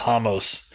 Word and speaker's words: Ramos: Well, it's spelled Ramos, but it's spelled, but it's pronounced Hamos. – Yeah. – Ricardo Hamos.